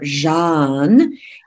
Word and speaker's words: Jeanne: So if you think of a Zsa Zsa Gabor and you add an N, not after the Gabor, not Gaborn, but an N after the Zsa Zsa part, Jeanne, you would be saying my name correctly.